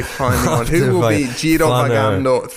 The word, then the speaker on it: Girovagando: I mean, looking forward to Girovagando. I'm going to keep saying it now that I've been told that my pronunciation's acceptable.